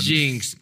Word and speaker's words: Jinx: Jinx.